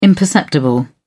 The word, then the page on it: imperceptible: British English